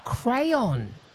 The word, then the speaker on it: crayon: Crown as in the one you draw with, and crown as in the one you put on your head. – Oh, crayon. Crayon, yes.